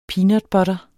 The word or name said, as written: peanutbutter